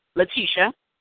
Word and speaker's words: Lataisha: My name is Letitia Richardson. – Letitia Richardson? – Yes. Uh-huh. – You sound like an actress. I'm Letitia Richardson. Is Letitia or Letitia? – Letitia.